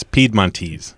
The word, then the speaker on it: Piedmontese: We hear it just about every way, but it's Piedmontese. – Piedmontese.